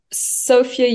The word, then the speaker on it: Sofya: Oh, um, Sofya Yokoyama.